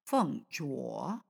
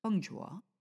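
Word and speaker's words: Feng jua (凤爪): how do you say that? Feng jiao? Feng jua. Feng jua. – Yes. – Feng jua.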